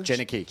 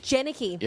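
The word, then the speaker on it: Jenneke: How do you say her last name? – Jenicky. – Jenicky. – Yeah.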